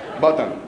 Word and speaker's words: button: Button, yeah, I said it right? Yeah, button, yeah. I was practicing that word a lot. Button, not with an M at the end, with N.